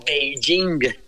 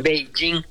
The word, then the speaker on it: Beijing: P-E-K-I-N. Uh-huh. Cuando la capital de China se pronuncia Beijing. – Sí. – Beijing.